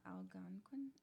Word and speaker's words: Algonquin: So a Wendigo comes from, I'm going to butcher this name, I'm so sorry, Algonquin folklore? – Algonquin? – Algonquin? – There is an N, yeah, Algonquin? – Yeah, that.